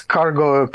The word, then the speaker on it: Cargó: It's cargo. It just sounds better to my ear when you put a stress on the last syllable.